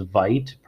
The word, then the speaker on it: Veidt: It's Veidt, pronounced, or rhymes with fight.